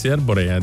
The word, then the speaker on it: Arborea: Sì, Arborea. – D'Arborea, vedi, ho detto bene.